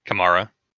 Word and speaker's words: Kamara: If Karma plays, not Karma, I can't pronounce his name. – Kamara. – Yeah, him. Thank you. Kamara plays.